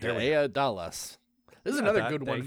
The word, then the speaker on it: Daedalus: Is it Daedalus? – Daedalus. Is that right? – Yeah.